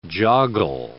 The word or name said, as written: joggle